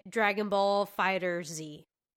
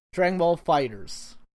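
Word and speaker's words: Dragon Ball FighterZ: I will say, like, back to pre-me before I was spoiled of the wonders of the universe, I would have pronounced it Dragon Ball Fighter Z. – That's what I said. Dragon Ball Fighter Z is what I thought. – That's how I used to pronounce it as well. – But now you know. – But they, promotional people have called it this, and now Namco has confirmed that it is indeed Dragon Ball Fighters, not Fighter Z, not Fighters.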